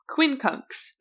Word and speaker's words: quincunx: That's Q-U-I-N-C-U-N-X. I had to look up how you pronounce this word. – Yeah, that's a funky word. – What it meant, because it ends in an X. I was like, how do you say that? Quincunx.